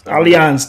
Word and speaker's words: Allianz: Allianz.